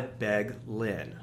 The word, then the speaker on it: Beglin: Which, by the way, Chad just told me a great way to remember how to pronounce his name, which is, if you want a part in Hamilton, you've got to beg Lynn.